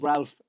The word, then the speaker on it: Ralph: Ralph is Ralph with an L in it, right? You have to pronounce the L. I once called Ralph Keyes Rave Keyes as a bit of a joke over a pint, and I got a punch in the chops for it.